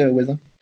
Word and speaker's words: hoazin: Comment ça s'écrit, oisin ? – C'est H-O-A-Z-I-N. – H-O-A-Z-I-N, incroyable, oisin, génial.